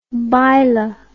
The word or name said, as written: baila